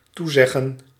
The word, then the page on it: promise: American English; [ˈpɹɑm.əs]; /ˈpɹɑ.mɪs/